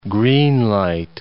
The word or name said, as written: green-light